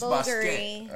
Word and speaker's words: Bulgari: Like, Bulgari. – It's Basquiat. – Basquiat. – It's Bulgari. Right, I had the same conversation with my friend that works for Bulgari.